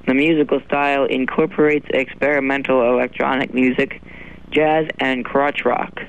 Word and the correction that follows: Krautrock: Krautrock. – That's pronounced krautrock. It's a German expression.